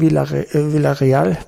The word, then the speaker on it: Villarreal: Es ist der Auftritt bei Villarreal heißt es ja, genau ausgesprochen, erinnerte mich an die Spielweise des Hamburger SV.